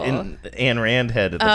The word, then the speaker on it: Ayn: And by the way, I know it's pronounced Ayn.